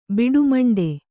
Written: ಬಿಡುಮಂಡೆ

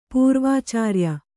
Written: ಪೂರ್ವಾಚಾರ್ಯ

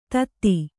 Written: ಥತ್

